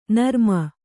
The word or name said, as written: ನರಮು